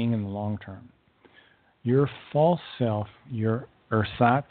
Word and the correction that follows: ersatz: self, let me pronounce that better, ersatz self, can never satisfy you in the long term.